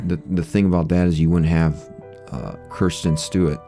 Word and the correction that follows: Kristen Stewart: Stewart. – The end of the saga of me saying her name wrong is coming to an end. – Kristen Stewart. Give her the respect she deserves.